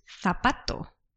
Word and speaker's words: zapato: But in some places in south of Spain and in Latin America, the Z is pronounced as an S. So probably, rather than zapato, you will hear some people saying zapato.